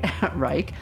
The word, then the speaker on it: Raij: I'm going to say that right. Raij. R-A-I-G. It looks different than it sounds. Raij.